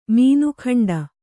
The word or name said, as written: ಮೀನಖಂಡ